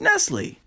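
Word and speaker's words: Nestlé: What I don't get is how would you even know that it's pronounced Nestle? There's an accent, but you cannot see.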